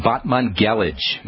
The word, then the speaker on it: Batmanghelidj: by the Iranian doctor Feridun Batmanghelidj. That's spelled, his last name anyway, spelled B-A-T-M-A-N-G-H-E-L-I-D-J.